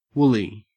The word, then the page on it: woolly: Australian English